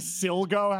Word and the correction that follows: Sligo: has a chance this season? – Sligo. – Sligo? Is that how you pronounce that? – Oh, boy.